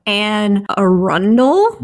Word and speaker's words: Anne Arundel: Now, by the way, everyone, it is spelled A-N-N-E, A-R-U-N-D-E-L. Try it one more time. – Anne Arundel? – You got it.